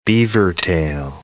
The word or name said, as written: beavertail